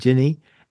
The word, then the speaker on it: Ginney: Go to the webpage, Ginny DeVol, D-E-A. Now, the first word is, I'm going to see if I spell it right, G-I-N-N-E-Y? – Yeah, Ginny. – And Ginny. And DeVol, D-E-A-V-O-L, one L.